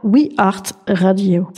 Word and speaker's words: We Art Radio: We Art Radio. Ça s'écrit art comme l'art.